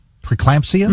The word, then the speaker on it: preeclampsia: My wife experienced postpartum preeclampsia, mm-hmm.